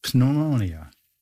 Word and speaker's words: pneumonia: Pneumonia. – Oh, they have a. – Here we go. Yes. – Yes. – They have silent. Oh. – Yeah. You're already there. – They're silent, silent letters.